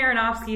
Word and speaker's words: Aronofsky: Aronofsky? – I think that's right. How it is. Yeah. – So Darren Aronofsky is the producer, and I'm like, all right, I trust him.